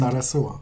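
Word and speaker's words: Zarazúa: Because there's an accent on the U. – Yes. – So it's Zarazua. – Believe me, yeah. I looked this up. And Zarazua.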